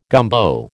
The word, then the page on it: gumbo: American English